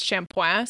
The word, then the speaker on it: shampooing: I think it comes from a Hindi word originally, but in French, it's like it's spelled shampooing, but it's shampoing.